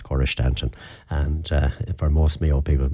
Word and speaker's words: Staunton: I have a feeling that that town may well have been named after somebody from County Mayo, because the traditional pronunciation of that name here in Mayo would be Stanton, a silent U, even though the more posh version of it would be the Staunton version of it, which a lot of RT folks and all of those like to use when they're talking about Cora.